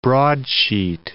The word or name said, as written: broadsheet